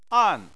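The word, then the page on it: an: tone 4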